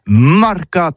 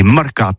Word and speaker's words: marcat: Eh bien, d'ici à tout, le mercat de l'Uni ou le mercat des rebelles, mais en gascogne toulousain, c'est prononcé sur les marcats, avec un A marcat.